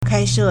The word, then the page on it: 开设: kāishè